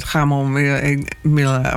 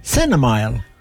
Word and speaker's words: chamomile: Chamomile of zo. Dus ik heb hem opzacht nou, en dan moest het zo uitspreken. Sure. – Chamomile. – Chamomile.